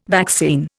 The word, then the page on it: vaccine: Indian